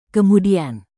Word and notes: kemudian: Indonesian

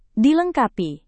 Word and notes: dilengkapi: indonesian

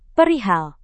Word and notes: perihal: Indonesian

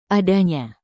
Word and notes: adanya: indonesian

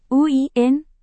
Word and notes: uin: Indonesian

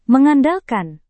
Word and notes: mengandalkan: Indonesian

